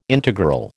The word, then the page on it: integral: American English